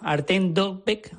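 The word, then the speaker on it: Artem Dovbyk: Artem Dobic, así lo pronuncian los comentaristas ucranianos en sus retransmisiones,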